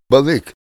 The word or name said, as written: balik